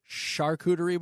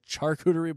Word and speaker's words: charcuterie: I guess it's a charcuterie board. – It is? Okay. – Instead of a charcuterie board.